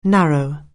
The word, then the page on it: narrow: anglais